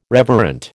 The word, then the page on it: reverent: American English